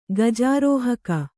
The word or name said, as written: ಗಜಾರೋಹಕ